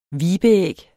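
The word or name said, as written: vibeæg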